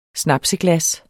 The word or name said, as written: snapseglas